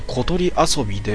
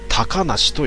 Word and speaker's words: 小鳥遊: 後でわかることだが、これは小鳥遊びではなく高梨と読む誰かの名字だ。